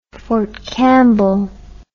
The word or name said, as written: Fort Campbell